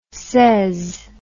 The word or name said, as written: sez